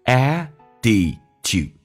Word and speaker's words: attitude: Have it, 剔去掉略音， with your 重度卷舌，整个嘴巴凸出来，来体验一下 your， one more time， your 最后一个单词叫 attitude， attitude，剔发 d 这个发音，很简单，两个元音中间所加的辅音要被浊化，来体验一下 attitude，我们重新来朗读一遍。